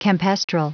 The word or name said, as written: campestral